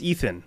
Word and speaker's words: Ethan: My name is Ethan. – How do you spell that? – E-T-H-A-N.